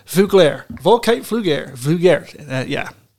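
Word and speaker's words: Veuglaire: Vuglare. – All right, good. – Vuglare. – Vuglare, you heard Volkite it. Volkite Vuglare. – We're never saying it again, but that is the proper pronunciation.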